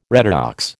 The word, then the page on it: redox: American English